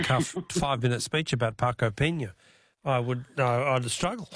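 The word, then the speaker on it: Peña: Marco Pena, with a little accent on the N, Pena.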